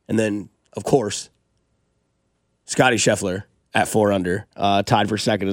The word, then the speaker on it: Scheffler: I like Adam Scheffley, too. – Yeah. – Which one did I butcher there? – Scheffler? – Scheffler.